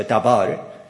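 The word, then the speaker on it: dabar (דבר): The Hebrew word for matter, the conclusion of the whole matter, the Hebrew word for matter is pronounced like a dabar. Dabar is often translated as word, W-O-R-D, which is translated as logos in Greek.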